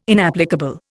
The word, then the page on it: inapplicable: Indian